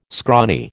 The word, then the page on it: scrawny: American English